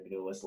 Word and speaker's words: Benulis: So it's Dr. Benjamin Benulis. Last name is spelled B-E-N, like my first name, U-L-I-S.